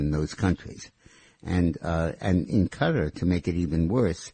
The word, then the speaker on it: Qatar: The country of Turkey and the country of Qatar, Q-A-T-A-R, pronounced Qatar.